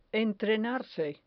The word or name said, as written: entrenarse